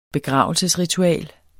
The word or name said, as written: begravelsesritual